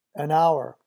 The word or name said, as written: an hour